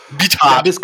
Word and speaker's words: Beathard: Oder ist es eben CJ Beathard oder wie er ausgesprochen wird? – Beathard.